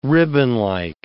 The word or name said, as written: ribbonlike